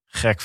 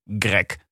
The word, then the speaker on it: Greg: Dus twee hoofdrolspelers in deze koers zijn een hele jonge Greg van Avermaat. – Ja. – Winzaam, ik soms vergeet dat ze me uitspreken als Greg en niet Grek.